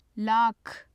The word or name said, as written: lakh